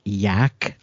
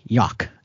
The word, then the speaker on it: yak: I was proofing an audiobook last week where the narrator pronounced yak like it rhymed with yacht instead of with track. So it was like yawk. And it's like, no, that is not how you say that word.